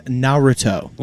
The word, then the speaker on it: Naruto: The correct pronunciation is Naruto.